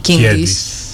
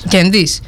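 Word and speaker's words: Kiedis: Λοιπόν, αυτό το τραγούδι λέει ότι ο τραγουδιστής τους, ο Άντωνι Κίντις. – Κέντις. – Νομίζω ότι Κίντις προφέρεται. – Δεν ξέρω. Ναι, ναι. Εμείς είμαστε μια παλιά γενιά. Προ-ίντερνετ, τα λέγαμε όπως θέλαμε. – Κέντις. – Ναι.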